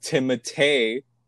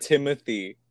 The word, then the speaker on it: Timothée: Nobody knew, at least I didn't know, his name was pronounced Timothée, not Timothy.